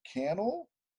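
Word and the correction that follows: Cannell: who created the A-Team. – Canal. – See, I can't do it.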